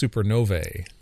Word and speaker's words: supernovae: But that's the plural of supernova is not supernovas. It's supernovae. Supernovae, I think, is the right way to do it.